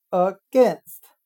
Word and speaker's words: against: a i 字母组合通常的发音是 a 的音，也就是 a b c 的 a，但在 against 这个单词里发 i 的音，所以大家一定要注意这个发音。